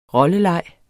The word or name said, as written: rolleleg